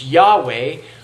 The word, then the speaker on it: Yahweh: is most likely pronounced Yahweh, or in a Germanized form, Jehovah.